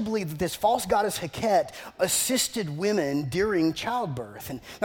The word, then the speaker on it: Heqet: One of the false gods that the ancient Egyptians were worshiping during the time of Moses was a goddess named Heket. Heket, H-E-Q-E-T, that's how you spell it, H-E-Q-E-T, you look it up, H-E-Q-E-T.